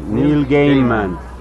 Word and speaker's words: Neil Gaiman: Es que, lo que de Neil Gaiman, no Neil Gaiman o Neil Gaiman, no, pronuncien bien, Neil Gaiman.